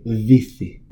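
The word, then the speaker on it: Vici: I loved hearing the announcer do that soft C all game. Vithi.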